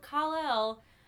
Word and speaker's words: Kal-El: Yeah, so we find out that Lex Luthor's descendants are actually Superman's parents, and they put little Kal-El, right? – Is that how you pronounce it? Yeah, you got it.